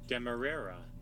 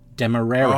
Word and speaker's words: Demerara: Demerara. – Demerara. – Oh, see that? I needed that. Because I had no idea how to pronounce it. – Because you're, well, you always put in an extra syllable, I think.